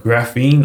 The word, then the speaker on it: graphene: So you did a post on something called graphene. I don't know if I'm saying that correctly. – Yeah, sure.